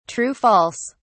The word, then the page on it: true-false: British English